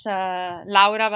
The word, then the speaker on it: Laura: Yeah, my name is Laura Valente. I'm from Italy. I have jokes sometimes saying about how I pronounce my name with an open ah, like in mamma and pizza.